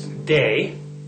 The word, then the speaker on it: Day: My name is Day, simple, D-A-Y.